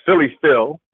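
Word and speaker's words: Fili Fil: My name, of course, is Philly Phil. And as always, we explain that that's spelled with an F, F-I-L-I. And the second word is F-I-L.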